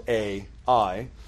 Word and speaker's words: Ai: And so the next battle that they have in the city of Ai, spelled A-I, some people pronounce it I, some people pronounce it A-I, they do some reconnaissance.